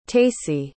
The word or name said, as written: tacey